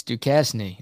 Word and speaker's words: Duquesne: It's how you pronounce Duquesne. – Oh, Duquesne. Oh, yeah. Now you're pronouncing it wrong. It's Duquesne.